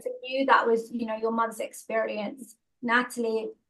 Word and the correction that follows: Natalia: I would love to hear, or Natalia, sorry, Natalia, let me pronounce that properly.